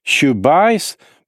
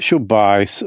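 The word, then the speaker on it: Chubais (Чубайс): Han heter visserligen Chubais, men det uttalas Chubais och under en för Ryssland viktig period satt han i duman, var vice premiärminister och slogs för att privatisera riket.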